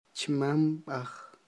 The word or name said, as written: chmamb'aj